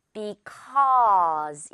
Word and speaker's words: because: 정말 많이 쓰는 말인데 많은 친구들이 이 부분 O라고 발음하는 것 같아요. Because가 아니라 Because. 입 크게 벌려요. 떡 받아 먹을 수 있을 만큼 Because.